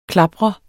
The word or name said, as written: klapre